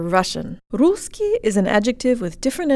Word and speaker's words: русский: Let's start with the second syllable of the masculine, русский. Jaw slightly forward, tip of the tongue behind the bottom teeth, lips rounded and protruded. Ки. Ки. English